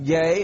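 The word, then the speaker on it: gay (γῆ): It's pronounced gay. It's spelled G-E, but it's pronounced G-H-A-Y as gay.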